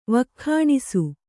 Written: ವಕ್ಖಾಣಿಸು

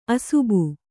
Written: ಅಸುಬು

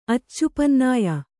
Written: ಅಚ್ಚುಪನ್ನಾಯ

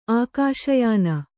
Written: ಆಕಾಶಯಾನ

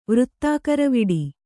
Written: ವೃತ್ತಾಕರವಿಡಿ

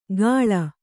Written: ಗಾಳ

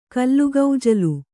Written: ಕಲ್ಲುಗೌಜಲು

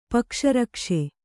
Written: ಪಕ್ಷರಕ್ಷೆ

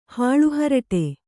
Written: ಹಾಳುಹರಟೆ

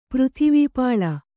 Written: ಪೃಥಿವೀಪಾಲ

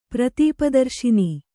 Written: ಪ್ರತೀಪದರ್ಶಿನಿ

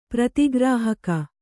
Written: ಪ್ರತಿಗ್ರಾಹಕ

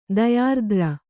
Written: ದಯಾರ್ದ್ರ

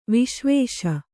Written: ವಿಶ್ವೇಶ

